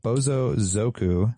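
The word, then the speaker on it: bōsōzoku: So, Bozozuku... I'm saying that wrong. Bozozoku.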